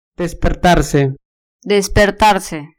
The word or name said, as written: despertarse